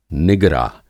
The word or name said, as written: निगरा